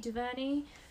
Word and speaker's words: DuVernay: Is it Ava DuVernay? DuVernay.